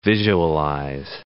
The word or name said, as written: visualize